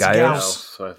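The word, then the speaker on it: Gyaos: Is it Gauss? – It's Gauss. – Gauss. – Gauss. – Yeah, Gauss.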